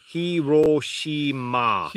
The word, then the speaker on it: Hiroshima: Hiroshima.